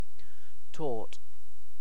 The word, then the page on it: tort: British English